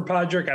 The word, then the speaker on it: Padraig: No, I got this one because there's a famous golfer named Padraig Harrington. So Padraig.